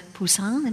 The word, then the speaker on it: Poussin: And in this most recent book, a wealthy landowner in Scotland, his painting by the French realist Nicolas Poussin, am I saying that correctly? – Yes, you are.